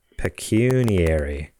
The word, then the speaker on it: pecuniary: Pecuniary? Is that a word? Google says that's how it's pronounced. Pecuniary.